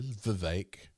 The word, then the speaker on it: Vivek: But now the talk is Vivek. I said it correctly. – Vivek.